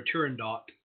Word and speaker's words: Turandot: It's like the opera Turandot. You do pronounce the T in Turandot. People like to be exotic and say the Puccini opera Turandot. Nope. Listen to it whenever they sing her name, the princess's name. Turandot, you do pronounce the T. The T is not silent in Italian. It would be if it were French.